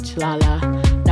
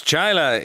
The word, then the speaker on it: Chaila: It's not Chile, it's not Chilala. Not hard pill to swallow. Chile or Chilea, Chia, Chila, Chila, that's not my name. Say my name. – Denise Chaila is her name,